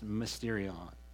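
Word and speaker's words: mysterion (μυστήριον): Paul's writings, all of Paul's writings, when he's talking about this mystery, it's a Greek word for pronounced mysterion.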